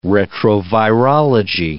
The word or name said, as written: retrovirology